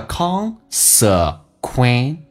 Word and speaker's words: consequently: Consequently 指的是因此结果，那么英式发音叫做 consequently, 美式发音叫 consequen,